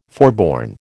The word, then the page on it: forborne: American English